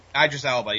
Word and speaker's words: Idris Elba: Idris Elba, yeah.